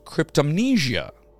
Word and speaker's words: cryptomnesia: This condition is known as cryptomnesia.